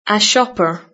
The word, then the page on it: shopper: British English